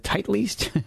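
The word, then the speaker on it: Titleist: How do you pronounce that? Is it tight leased? – Yeah. – I've been saying it wrong all this time.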